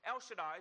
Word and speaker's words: El Shaddai: which is in the Hebrew, you pronounce it something along the lines of El Shaddai.